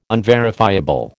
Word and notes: unverifiable: American English

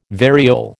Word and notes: variole: American English

